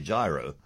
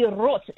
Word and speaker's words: gyro: But G-Y-R-O, as in the Greek bat with chips and lamb. – I think this is where you and I might part ways because I think I'd say gyro. – And you would be so ignorant. It is gyro. Ideally, gyros, in fact.